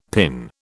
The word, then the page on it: PIN: British English